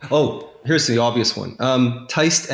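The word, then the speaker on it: Tiste: Think of the word Teist as I wrote it. It's T-I-S-T-E. Well, it's a long I, and that's why you have the E at the end of it. If you took the E off, it would be Tist.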